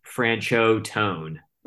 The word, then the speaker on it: Franchot Tone: It's actually pronounced Franchotone. – Okay. – That's how it's pronounced.